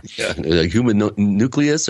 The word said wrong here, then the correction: homunculus: How do you say that? – A homunculus, right.